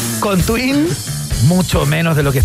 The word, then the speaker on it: Tuin: T-U-I-N, tal cual suena.